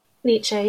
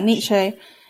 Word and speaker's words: Nietzsche: Nietzsche. Is that the author's word? Like the philosopher? – Nietzsche, yeah. – Yeah, Nietzsche.